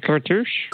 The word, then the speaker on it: cartouche: You mean the cartouche? – Cartouche. There we go. – Yeah, I should remember cartouche because rhymes with douche.